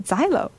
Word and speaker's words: Xilo: The first song is called Xylo. Ooh. Mm. It's spelled X-I-L-O, not X-Y-L-O, but I still call it Xylo, and it's my song.